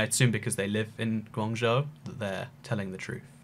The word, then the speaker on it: Guangzhou: Is it Guangzhou? – Guangzhou.